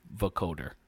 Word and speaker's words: vocoder: And 6 out of the 9 they're just like nonstop vocoder.